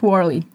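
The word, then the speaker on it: Warely: It's W-A-R-E-L-Y. – That's Worley.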